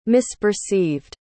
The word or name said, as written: misperceived